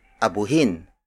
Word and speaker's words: abuhin: Nowadays, we use the Tagalog word abuhin.